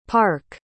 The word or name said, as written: parke